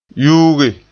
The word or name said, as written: yuuge